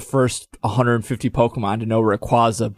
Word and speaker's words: Rayquaza: Another one is Rayquaza, because Rayquaza's actual pronunciation is, do you know what it is? – No, I don't. I thought it was Rayquaza. – Nope, it's Rayquaza.